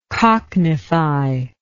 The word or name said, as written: cockneyfication